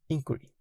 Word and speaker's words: Inquiry: ¿Cómo se dice? pronuncia eso? Inquiry. Inquiry una organización estadounidense que promueve la ciencia, la razón y los valores humanistas.